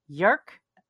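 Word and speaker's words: Jörg: So the guy who runs it, and I checked pronunciation, his name is Yerk, which rhymes with Kirk, he pointed out, Hillebrandt. But it's J-O-R-G, and I'll put a link to his account.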